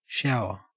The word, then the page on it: shower: Australian English